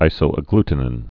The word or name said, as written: isoagglutinin